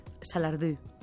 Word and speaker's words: Salardú: Salardí. – Salardú. – Bé, ho he pronunciat malament.